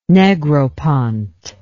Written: Negropont

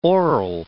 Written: oral

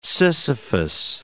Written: Sisyphus

